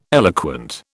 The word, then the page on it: eloquent: British English